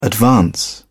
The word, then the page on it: advance: British English